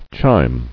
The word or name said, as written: Chime